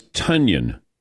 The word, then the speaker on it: Tonyan: Came out and said that his name rhymes with Funyan and that the Green Bay media has been doing it wrong all along. – So it's Tonyan.